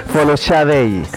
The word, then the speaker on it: Folashade: Su nombre es Ellen Folashade Adu, que así se pronuncia en eso, en ese idioma, Folashadei.